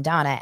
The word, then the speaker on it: Dana: Dana Mandel is her name, which is funny because it's spelled D-A-N-A, which is how I spell my name, even though I'm Donna, and her last name is Mandel, which is my mom's maiden name.